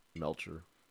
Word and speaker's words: Melcher: I'm pretty sure it's not Melker, isn't it? Like Melcher. – Melcher. However you want to pronounce it.